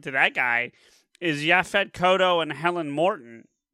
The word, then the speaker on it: Kotto: Yaphet, I think it's pronounced Kotto, just for clarification. – Sure. – Yaphet Kotto.